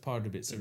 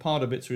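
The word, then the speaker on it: Pardubice: It's also a problem that Pardubica is four syllables.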